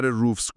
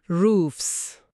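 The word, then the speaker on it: roofs: حالا به صدای آخر روفز گوش کنید. صدای FS میده. – روفز.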